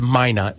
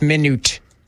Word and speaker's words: Minot: Yeah, one pronunciation. It's Minot.